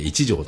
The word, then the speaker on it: 一条: あさっては東武東上線の上福岡という駅にあります音喫茶一条、一つ乗ると書いて一条と読むんですが、